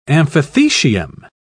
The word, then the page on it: amphithecium: British English